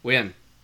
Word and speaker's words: Nguyen: No. Nguyen. It's almost two syllables.